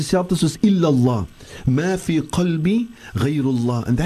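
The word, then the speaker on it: qalbi (قلبي): Kalbi means my hond. No, we don't want to say that. That is why it's so important that we go to ustaz and learn how to pronounce the different huruf.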